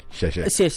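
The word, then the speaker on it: xie xie (谢谢): And you probably even said it wrong. – Xie xie. – It's all about intonation. – Exactly. It's more like xie xie.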